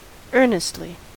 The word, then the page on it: earnestly: American English